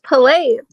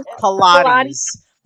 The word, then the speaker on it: Pilates: I thought they were pronounced Pilates. – No, it's not. It's pronounced Pilates. Pilates.